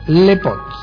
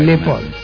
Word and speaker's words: Lepaute: Luego ya nos venimos un poquitito más acá en el tiempo y tenemos a la astrónoma Nicole Rein Lepot. – Qué menos mal que te tengo ahí apuntador. – Bueno, de francés me acuerdo todavía. – Lepot, muy bien. – Pero se escribe Lepaute.